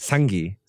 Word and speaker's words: Senge: I'm pretty sure that's how you say his name because it sounds really similar to my middle name. – It does. – Senge.